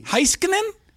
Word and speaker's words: Heiskanen: Heiskanen. There's no J involved there, Heika.